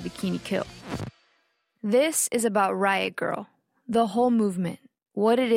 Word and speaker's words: riot grrrl: The movement came to be called Riot Girl. That's girl spelled with no I and three R's. It's meant to be pronounced with a sort of growl.